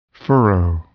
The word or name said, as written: furrow